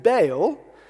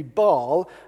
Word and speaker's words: Baal: I was always taught to call him Baal. I've heard lots of people call him Baal, and other people call him Baal. So I looked it up. The Oxford English Dictionary says that you should pronounce it Baal, but apparently the American pronunciation tends to be Baal, and they claim that's closer to the original Hebrew.